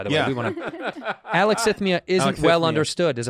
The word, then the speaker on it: alexithymia: It's alexithymia.